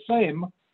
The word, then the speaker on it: Sejm: In the dominant 460-member National Assembly, the same, pronounced same, S-E-J-M, pronounced same, Solidarity candidates won all 161 contested seats.